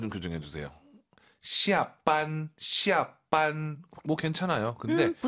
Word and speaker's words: 下班: 힘을 좀 빼고 하시는 게 좋을 것 같아요. 아하. 너무 쌍비읍이 강하면 좀 별로예요. – 要更自然一点. – 해볼게요. – 下班. – 이거 이거. 어? 지금 왕 선생님도 쌍비읍 아닌가요? 어, 비슷해요. 근데 완전 우리가 알고 있는 반 이건 아니에요. 비읍과 쌍비읍의 중간, 시아반 이게 좋을 것 같습니다.